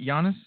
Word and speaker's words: Giannis: So the Giannis and – am I saying that right, Giannis? – Yes.